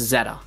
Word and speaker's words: Z-A: And they saw that the Japanese, I don't remember if it was the site or the Twitter or something, but they actually, like, put it in the way that you would pronounce it. It's a little bit easier to tell in Japanese, and it's Zeta.